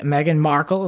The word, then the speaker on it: Meghan Markle: Prince Harry, Meghan Markle, is that how you say her name? – Yes.